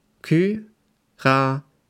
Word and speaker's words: Curaçao: Deutschland hat gegen das Land Curaçao gespielt. Das wird so ausgesprochen. Curaçao.